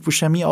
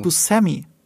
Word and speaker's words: Buscemi: Es wird nicht Steve Buscemi ausgesprochen oder Steve Buscemi. Steve Buscemi ist es.